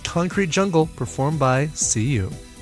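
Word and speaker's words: Céu: And we're going to kick things off tonight with a cover by Seiyu. I believe that's how it's pronounced. C-E-U.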